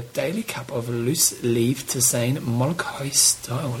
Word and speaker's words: tisane: What, how do you say that? – Tisane? – Tisane.